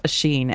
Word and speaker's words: Oisin: Your name is spelled Oisin, and it's pronounced Ashin, and it's very disconcerting.